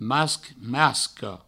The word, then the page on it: masc: masca; masco